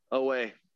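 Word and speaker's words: Oweh: Baltimore Ravens, Jason Owoe. Did I say that right? – Owoe.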